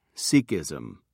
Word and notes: Sikhism: British English

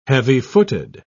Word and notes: heavy-footed: British English

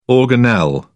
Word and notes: organelle: British English

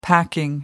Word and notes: packing: American English

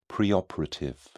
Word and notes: preoperative: British English

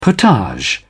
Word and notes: potage: British English

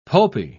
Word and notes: pulpy: British English

